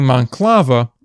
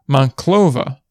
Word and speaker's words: Monclova: See, I was pronouncing Monclova as Monclova because I thought it was pronounced Monclova. Apparently, it's pronounced Monclova. So a slight difference, but a difference nonetheless.